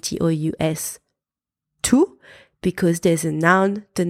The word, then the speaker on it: tous: You pronounce T-O-U-S tous when there is no noun after it. For example, ils sont tous partis. You pronounce T-O-U-S tout when there is a noun after it. Par exemple, tous les enfants vont à l'école.